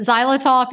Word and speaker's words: xylitol: And just for listeners, because it's spelled not necessarily like it sounds, but xylitol, if you're looking at ingredients of things, are you worried maybe your animal at them, it's X-Y-L-I-T-O-L.